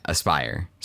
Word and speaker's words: Aspyr: Okay, that's comforting, but Aspyr? Yeah, Aspyr. Spelled with a Y and no E at the end.